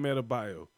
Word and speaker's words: Adebayo: Adebayo or Bam Adebayo.